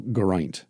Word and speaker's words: Geraint: But he said it's G like egg and Reint like pint. So Geraint.